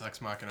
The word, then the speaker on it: Ex Machina: Yes, Ex Machina.